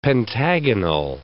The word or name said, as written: pentagonal